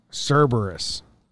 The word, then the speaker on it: Cerberus: Cerebus? Does it start with a C? – Yeah, Cerebus. – C-E-R-E-bus? – Close. – Cerberus? – Cerberus. – Cerberus. – I'm saying it. – Cerberus. – Yeah, Cerberus.